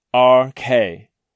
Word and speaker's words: arche: The word is arche, pronounced R-K, and it means beginning or origin.